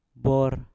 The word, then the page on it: bor: mpur